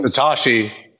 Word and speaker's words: Satoshi: Satoshi. His name is Satoshi. He pronounced it wrong.